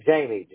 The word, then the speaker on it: Jamie: All right, Jamie, J-A-M-I-E, Ridley, R-I-D-D-L-E-Y.